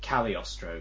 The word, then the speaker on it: Cagliostro: I think it's like Cagliostro, because it's meant to be Italian, but...